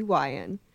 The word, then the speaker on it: Wion: Please welcome Darcey Wion.